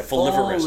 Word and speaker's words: folivorous: If I can pronounce it, folivorous. – Folivores, yeah, folivorous. – Folivorous. Okay.